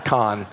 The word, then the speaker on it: Kahan: By the way, at the 9 a.m., I pronounced it Noah Kahan, and so many young people came up and were like, hey, you're an idiot. It's not Kahan, it's Kahn, okay?